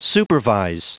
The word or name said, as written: supervise